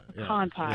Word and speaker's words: pecan: We can lay to rest that it's pronounced pecan pie, absolutely. – Right? – Absolutely. – Yeah, we got to draw out the A a little bit.